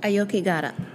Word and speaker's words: Aokigahara: How do you say it? Okogare? Okoka, the mountain in Japan? – Aokigahara. – Aokigahara, the Sea of Trees.